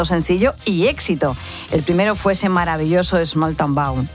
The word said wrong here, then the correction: Small Town Boy: Small Town Bow. Hoy voy, hoy se lo digo bien. – A ver, ¿cómo es? – Small Town Boy.